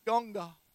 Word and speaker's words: Ganga: River Ganges. They pronounce it Ganga.